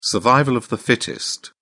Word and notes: survival of the fittest: British English

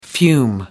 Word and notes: fume: American English

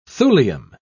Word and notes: thulium: British English

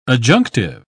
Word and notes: adjunctive: British English

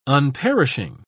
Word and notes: unperishing: British English

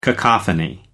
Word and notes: cacophony: American English

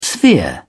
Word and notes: sphere: British English

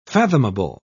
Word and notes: fathomable: British English

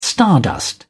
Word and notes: stardust: British English

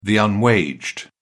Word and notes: unwaged: British English

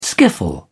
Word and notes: skiffle: British English

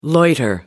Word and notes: loiter: American English

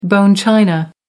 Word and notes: bone china: American English

